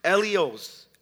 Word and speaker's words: eleos: So when I looked up the word mercy, in the Greek language, mercy is pronounced eleos, eleos, literally translated into our English, meaning kind, sympathetic, compassionate.